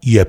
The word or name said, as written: Yep